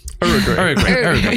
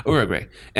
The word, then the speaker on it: Uruguay: Uruguay. – Yeah, that's how it's pronounced. Uruguay.